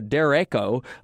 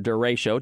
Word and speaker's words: derecho: Of course, that system started in New Orleans, very reminiscent of something that meteorologists call a derecho, or derecho, depending on how you want to pronounce it.